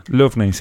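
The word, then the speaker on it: Loughnane: Y Lee Lufnane. ¿Estoy pronunciando bien? – Lufnane, sí.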